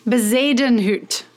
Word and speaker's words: Bezuidenhout: I need a little pronunciation here, because you worked for the European Ryder Cup, but Christian Bezuidenhout.